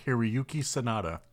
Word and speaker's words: Hiroyuki Sanada: Hiroyuki Sanada.